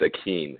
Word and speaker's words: Akin: It's just pronounced weird, so people messed it up when I was growing up. It's Akeen, A-K-I-N.